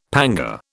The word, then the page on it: panga: British English